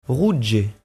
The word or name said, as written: rouge